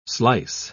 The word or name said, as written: slice